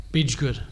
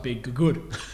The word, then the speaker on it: Bidgood: Bidgood. – Oh yeah, because I thought it was like Big Good. – The double G.